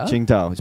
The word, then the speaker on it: Tsingtao: Tsingtao. – Tsingtao.